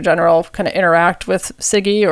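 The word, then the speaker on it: CIGIE: which I think you said is pronounced SIGI. – SIGI, yes.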